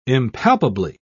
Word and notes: impalpably: British English

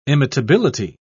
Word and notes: imitability: British English

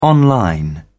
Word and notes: online: British English

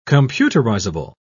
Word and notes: computerizable: British English

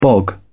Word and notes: bog: British English

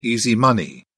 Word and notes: easy money: British English

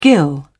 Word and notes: gill: British English

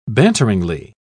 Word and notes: banteringly: British English

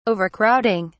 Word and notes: overcrowding: British English

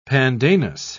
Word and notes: pandanus: British English

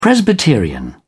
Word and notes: presbyterian: British English

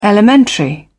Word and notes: elementary: British English

